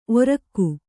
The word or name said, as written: ಓರ್ಗೈ